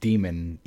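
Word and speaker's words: daemon: And apparently it is still pronounced demon, even though it's DAE.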